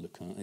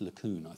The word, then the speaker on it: LeCun: Jan once told me how you pronounce his name, and it isn't LeCun, it's LeCun, I think.